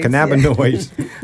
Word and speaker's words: cannabinoids: Am I pronouncing that right? – Cannabinoids. – Cannabinoids, yeah.